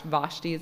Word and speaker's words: Vashti: Yes, the queen, the, see, Esther Vashti, Vashti is how you say it,